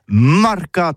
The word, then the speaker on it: marcat: Eh bien, dis ça à tous, mais le mercat, ou le mercat de l'Uni, ou le mercat des rebelles, mais en gascogne toulousaine, c'est prononcé sur les marcat, avec un A, marquette. En gascogne toulousaine, bah, du côté, par exemple, de l'îlot des Bâches, c'est l'île Jourdain, pour parler du marché, vous prononcez les marcat.